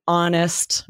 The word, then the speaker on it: honest: an honest man, even though H is a consonant because right. It's a vowel sound.